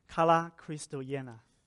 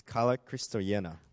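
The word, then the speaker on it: Kala Christoyena (Καλά Χριστούγεννα): 那用新约的语言是用希腊文写成了，希腊文怎么说呢？And New Testament is written in Greek, so how do you say Merry Christmas in Greek? – Kala Christoyena. – It's Kala Christoyena.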